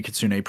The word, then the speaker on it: Kitsune: So so obviously you are Kenny Kitsune, pronouns he, him.